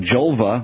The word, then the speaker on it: Jolva: But also, the other thing is, you have this product called Jolva, which rhymes with vulva, which sounds like it could be a remedy for women to start using right away.